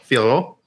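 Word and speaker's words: Fiorot: I know we have high expectations for Fioro. Is that how you say it? – Yeah. – Fioro. – It's Fioro. Fioro.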